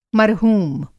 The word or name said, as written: मरहूम